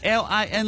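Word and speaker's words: Lin Koo: 唱歌的朋友咧，根据我哋嘅读音咧系咁样嘅，L I N Lin 嘅第一声即系 Lin 啦，跟住就 K O O 即系 Cool 嘅第四声 Cool Cool Cool Cool Lin Cool，系啦，唱歌嘅朋友就 Lin Cool，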